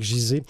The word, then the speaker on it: Gizeh: C'est comme ça qu'on le prononce, Gizeh? – Certains disent Gizeh, mais j'ai déjà entendu Gizeh également. – Vous me rassurez. Alors j'y vais avec Gizeh.